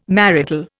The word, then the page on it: marital: Indian